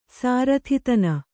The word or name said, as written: ಸಾರಥಿತನ